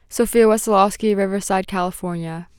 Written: Sofia Wesolowski

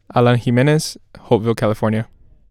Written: Alan Jimenez

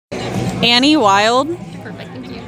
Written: Annie Wild

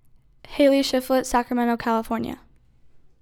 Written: Hailey Shiflett

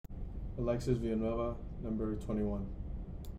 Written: Alexis Villanueva Zavala

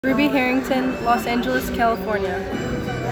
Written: Rubi Harrington

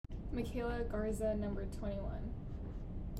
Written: Makayla Garza

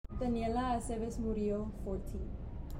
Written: Daniela Aceves

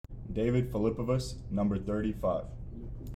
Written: David Filipovas

